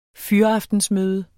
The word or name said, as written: fyraftensmøde